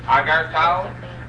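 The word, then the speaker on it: Agar Tal (אגרטל): Aleph, Gimel, Reish, Tes, Lamed. Agar Tal.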